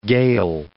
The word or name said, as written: Gael